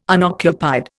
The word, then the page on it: unoccupied: Indian